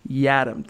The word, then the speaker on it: Yiadom: For fans wondering, since it is a curiously spelled name, Isaac himself, I heard him do it on Saturday, pronounces it Yadam. Think Adam with a Y.